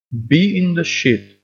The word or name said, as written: be in the shit